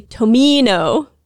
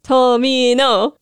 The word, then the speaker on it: Tomino: And when people do a chant, they put the same emphasis on every syllable. And it actually sounds much closer to the Japanese pronunciation. Tomino.